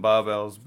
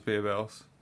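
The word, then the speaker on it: Barebells: I always just called them barbells, but they do pronounce themselves as beer bells.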